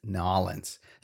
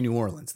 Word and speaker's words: New Orleans: Uh, it refers to the events of the Carnival celebration in Nolens. That's how they say it there. They don't say New Orleans. They say Nolens.